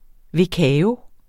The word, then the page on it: vekao: veˈkæːo